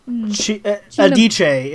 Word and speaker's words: Adichie: Adichie is how I was told that the last name is pronounced.